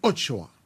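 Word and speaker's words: axoa: Alors ça s'écrit O-X-O-A, mais ça se prononce ochoa.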